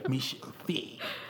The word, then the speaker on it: Misha V: You said, do we say it like this, Misha V? That's how we're gonna do it now? – Yeah. – Put the V. – Emphasis on the V. – Yeah, Misha V.